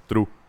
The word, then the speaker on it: trve: los que escuchamos de todo los denominamos true. Se escribe T-R-V-E.